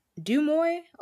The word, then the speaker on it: Deuxmoi: And it was about, I think it's called Dumoy, or Do, like, I would pronounce it Do, but it's DEUXMOI.